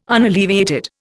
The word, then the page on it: unalleviated: Indian